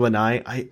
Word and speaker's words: Lanai: I mean, because, Molokai and Lani are, both very, or Lanai, I'm pronouncing these incredibly wrong.